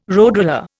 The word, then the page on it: roadroller: Indian